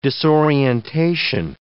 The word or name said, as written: disorientation